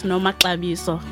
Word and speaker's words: Nomaxabiso: Okay, I'm going to pronounce it, and I'd like to try and pronounce it as well. Okay, it is pronounced as Nomaxabiso.